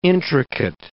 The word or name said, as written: intricate